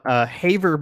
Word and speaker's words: Havre: This one is from Haver, Montana. That's, Haver. Oh, shit. I just looked up how to pronounce it. H-A-V-R-E, which is, I think, Haver Montana, based on what I saw.